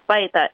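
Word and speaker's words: bahidaj: So, for the Sonoran, my tribe, this time of year is when we go out and pick saguaro fruit, which we pronounce baitach.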